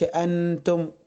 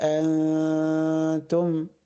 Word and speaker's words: أنتم: لا أظهر النون في النطق. ولا أنتم، ما أقولش أنتم تبقى ظاهرة كده، لا أقول ولا أنتم، أنتم يبقى اللسان في النص، لا هو فوق في سقف الحلق ولا هو تحت خالص، في النص كده أنتم.